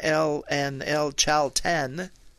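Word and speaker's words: El Chaltén: And we're going to go for another 3 hours to get between El Calafate and El Chalten. – Very nicely pronounced.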